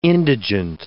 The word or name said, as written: indigent